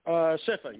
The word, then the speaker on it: Shifa: Shifa, yes.